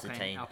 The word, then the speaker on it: Alpine: Alpine, I think that's how you pronounce Alpine.